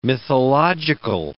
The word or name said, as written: mythological